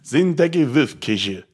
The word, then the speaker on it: Shinteki Fufukuju: It's actually pronounced Shinteki Fufukuju. – There you go. – And that is actually Japanese, not Swedish, Japanese for civil disobedience.